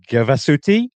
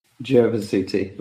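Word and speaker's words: Gervasutti: There's one featured in the book, I think, if my pronunciation is correct, it's the Bivaco Gavasuti. – Gavasuti, but close.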